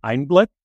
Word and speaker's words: Einblick: I believe the company is pronounced Einblick, so kind of a German name, meaning insight, if I understand correctly.